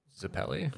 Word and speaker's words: Zeppeli: Zeppeli.